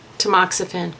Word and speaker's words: Tamoxifen: Tamoxifen? – Tamoxifen, correct.